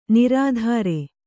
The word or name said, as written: ನಿರುತ್ತರೆ